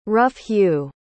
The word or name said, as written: rough-hew